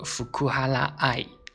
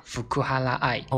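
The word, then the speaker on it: 福原愛: 就是啊，福原爱呢读作福库哈拉爱啊，福库哈拉爱哦，福原爱的爱称是爱酱，就是福库哈拉爱の愛称は愛酱。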